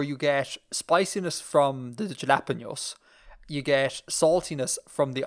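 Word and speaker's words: jalapeños: Jalapenos. There's a little tilde over the end.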